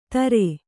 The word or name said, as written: ದರೆ